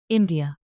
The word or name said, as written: india